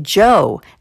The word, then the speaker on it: Djo: I also played Joe, that's spelled D-J-O, and the song Gloom off a new album due this fall called Decide.